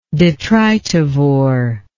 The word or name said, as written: detrivorous